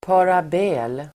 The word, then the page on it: parabel: par'a:bel